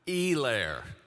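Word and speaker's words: Helaire: Let me pronounce it correctly for you. Edwards Hilaire. Not Hilaire, all right? Hilaire. That's how you pronounce it.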